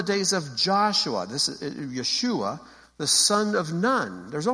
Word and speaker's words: Yeshua: Yud, Shin, Vav, Ayin, Yeshua.